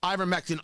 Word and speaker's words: Ivermectin: Ivermectin also has a formulation that works for animals.